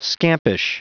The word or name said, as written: scampish